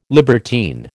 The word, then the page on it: libertine: American English